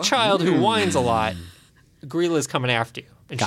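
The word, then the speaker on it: Grýla: Again, something that I looked up the pronunciation of. There's a whole website where you type in Icelandic words and it'll spit it back to you on how you're pronounced. So this is pronounced Grilla.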